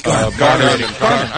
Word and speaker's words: Garman: When you have Ralph Jarman. – Garman. – I'm sorry. I keep doing that. – Hard G.